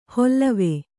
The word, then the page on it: ಹೊಲ್ಲವೆ: Kannada